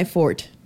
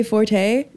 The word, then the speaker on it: forte: It's not my forte. And I'm actually saying that right. People say forte. That's not even accurate. Forte is Italian. It means to get louder. Fort is French for strong. Or, you know, your strong suit.